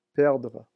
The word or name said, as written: perdre